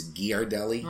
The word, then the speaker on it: Ghirardelli: Now, for years, I called it Ghirardelli. But apparently now it's a hard G, so it's Ghirardelli, right.